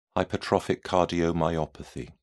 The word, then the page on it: hypertrophic cardiomyopathy: British English